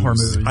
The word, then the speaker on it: Hormuz: But no, you never hear Hormuz, is what we're saying. It's always been Hormuz.